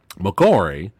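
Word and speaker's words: Makoare: But Lawrence, excuse me, McCory, I think I said, did I say it wrong? – Yeah, no, you said it right.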